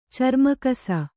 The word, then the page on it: ಚರ್ಮಕಸ: carma kasa